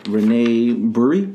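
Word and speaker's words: René Burri: I'm not saying they're your only two, but Andre Curtez and Rene Burri.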